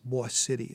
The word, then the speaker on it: Boise City: There was one storm in a town called, it looks like it's Boise City, but it's actually pronounced Bois City is the way they pronounce it.